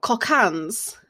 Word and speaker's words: Cockaigne: So just to let you guys know, it's spelled C-O-C-K-A-I-G-N-E. – Yeah. I YouTubed it before this and she did say it was like cocaine.